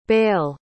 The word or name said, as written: Bâle